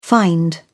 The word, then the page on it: find: British English